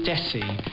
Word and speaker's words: Desi: So it's Desi. And it's like rhymes with messy. That's the best way to do it. So aim for Lionel Messi and say Desi.